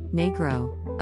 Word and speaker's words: negro: And Italian nero black, and is the source, via Spanish negro, pronounced negro, of English negro, negro, and the now taboo nigger.